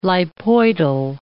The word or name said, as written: lipoidal